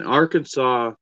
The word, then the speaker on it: Arkansas: In Arkansas, you must pronounce Arkansas's correctly. – Not Arkansas? – Not Arkansas. – Arkansas? – Arkansas? – No. Yeah. – Arkansas. – Pronunciation with the accent on the second syllable with the sound of an A in man and the sounding of terminal S is an innovation to be discouraged.